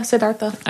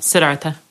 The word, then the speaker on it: Siddhartha: I was thinking about when they talked about Buddhism and they said that when Siddhartha, is that how you say it? – Yeah, Siddhartha. – Okay, Siddhartha.